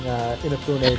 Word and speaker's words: Inafune: I thought it was Unifane. I have heard it pronounced Unifane on the Game Makers thing. – How do you pronounce it? – Inafune.